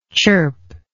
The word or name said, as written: chirpingly